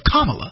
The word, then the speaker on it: Kamala: but she calls herself Kamala.